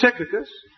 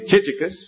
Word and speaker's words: Tychicus: with our disposition to say CH means ch, and therefore it must be Chichicus or something like that. But it's a hard sound that comes out that we are told by people who never heard Chichicus ever pronounce his name.